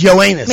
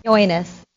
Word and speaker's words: Yoenis: His name is Yoenis. – Maybe I'll just call him Cespedes. – No, no, say his name. – Yoenis. – Into the microphone. – Yoenis. – Yoenis. It's yeah. Like saying, yo, ass.